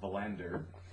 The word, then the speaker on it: Wallander: Um, although everybody has posh British accents and they pronounce things with British uh, phraseology, which is why it's Wallander and not Valander.